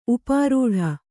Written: ಉಪ್ಪರಟಿ

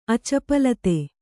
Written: ಅಚಪಲತೆ

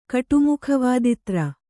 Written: ಕಟುಮುಖವಾದಿತ್ರ

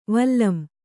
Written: ವಲಂ

